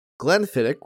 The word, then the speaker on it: Glenfiddich: Good people of Faerun, Travancore's choice this evening is Glenfiddich, which I had to look up the pronunciation of. It's not I-C-H, it's I-K in terms of the pronunciation of it.